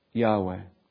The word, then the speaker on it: Yahweh: So that's part of the reason why we believe that the four consonants, the sacred tetragrammaton, is pronounced Yahweh.